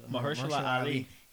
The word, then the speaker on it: Mahershala Ali: But Mahershala Ali, did I say his name right? – Mahershala Ali.